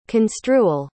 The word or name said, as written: construal